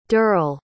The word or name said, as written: dirl